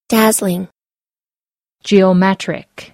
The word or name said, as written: dazzling